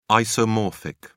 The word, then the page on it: isomorphic: British English